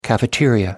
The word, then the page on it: cafeteria: American English